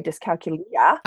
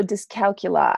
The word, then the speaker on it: dyscalculia: Apparently I say it the British way, dyscalculia. – Oh. – But it's also dyscalculia, I think, is also just another way of saying it.